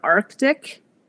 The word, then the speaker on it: Arctic: But a great example of how actually spelling misled people into adopting an entirely new pronunciation is in the word Arctic. Which actually did not have the C, the first C in historically, but because it was spelled in the 1800s with a C, it became pronounced that way because people thought that was the correct way to pronounce it.